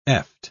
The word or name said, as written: eft